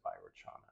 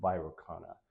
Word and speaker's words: Vairocana: Now, is it Vairocana or Vairochana? You decide. Comment below. Anyway. I think I've heard people say Vairochana, but then someone corrected me when I said Vairochana. I said, no, Vairocana.